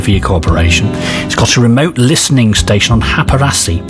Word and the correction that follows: Hapasaari: I'm not saying that right. Hapasaari it is, an island in the Gulf of Finland, where he says, I can tune in without interference from human-made disturbances.